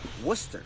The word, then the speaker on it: Worcester: It's pronounced Worcester. That's right. – They don't pronounce their R's.